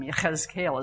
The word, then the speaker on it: Yechezkel: Yechezkel is how you say it in Hebrew.